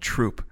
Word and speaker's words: Throop: It's spelled Throop, but okay. It's pronounced Troop. And if you say Throop, you'll get stabbed.